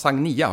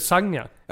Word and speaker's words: Sagnia: Som, det ska uttalas Sagnia har jag lärt mig. – Jaha, jag tänkte Sagnia. – Ja, precis. Jag tror att hon själv sa någon gång att det ska rimma på Sangria.